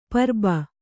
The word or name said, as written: ಪುರ್ಬು